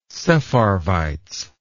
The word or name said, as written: sepharvites